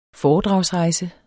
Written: foredragsrejse

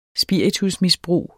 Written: spiritusmisbrug

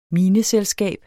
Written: mineselskab

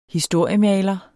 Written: historiemaler

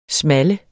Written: small

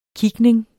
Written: kigning

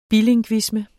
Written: bilingvisme

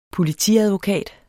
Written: Politiadvokat

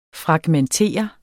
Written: FRAGMENTER

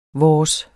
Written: vores

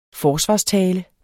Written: forsvarstale

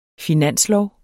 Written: finanslov

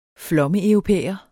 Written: flommeeuropæer

